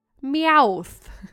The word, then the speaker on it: Meowth: Belonging to Team Rocket, the Pokemon character that loves to collect shiny things is Meowth. Is that how you say it? – That's how you say it.